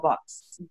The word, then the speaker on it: Vox: I mean, to give you an example, we have a political party here called Vox, V-O-X. They call it Vox because, you know, V is pronounced B.